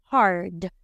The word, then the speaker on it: hard: H, como ¿qué les dije a la H? Hello, suena jajaja. Ja, ja. A cerradita, ja. R suavecita, ra. D final fuerte, hard, Acuérdense que las des finales hay que pronunciarlas, ¿ok?